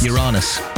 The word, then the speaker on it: Uranus: Uranus.